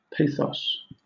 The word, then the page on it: pathos: British English